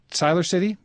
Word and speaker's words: Siler City: Let's go to, is it Siler City, North Carolina? – Yes, it is.